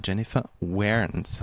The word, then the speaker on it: Jennifer Warnes: Da haben wir noch mit dem Joe Cocker und der Jennifer Warnes.